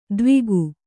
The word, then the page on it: ದ್ವಿಗು: dvigu